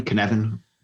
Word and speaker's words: Cynefin: Have you heard of Kinevin? Do you know what I'm talking about here? All right. – No. – So Cynefin, Kinevin, spelled like it sounds. – Okay. – It's a Welsh word.